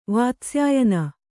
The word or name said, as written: ವಾತ್ಸ್ಯಾಯನ